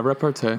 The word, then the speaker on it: repartee: Like he's just, it's just one funny, like, repartee. Is that how you say it? Or repartee? – Yeah, repartee.